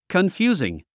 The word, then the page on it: confusing: kənfjúːziŋ; コンフュージン